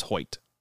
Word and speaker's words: Hoyt: Is that how we pronounce the name? – Yes, Hoyt? I heard it on the broadcast. It is Hoyt. So Michael Hoyt.